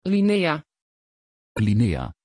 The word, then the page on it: Lynea: Dutch